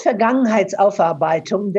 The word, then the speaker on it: Vergangenheitsaufarbeitung: It's called Vergangenheitsaufarbeitung.